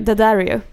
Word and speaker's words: Daddario: Det är alltså Matthew Daddario, eller hur man nu uttalar hans namn. – Daddario. Matthew Daddario.